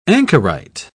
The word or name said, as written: anchorite